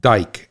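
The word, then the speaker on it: Dyck: A while back, Mr. Allen, and I'm going to pronounce it Dyke, and his name is spelled D-Y-C-K. I don't think it's Dick. I think it's Dyke, and he's from Western Canada.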